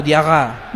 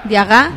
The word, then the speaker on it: Diarra: Pero ellos pronunciarán la doble R o como R sola. – Ellos lo pronuncian como Diarra. – Diarra. – Diarra. – Como francés. – Como francés, claro, porque son colonias francesas.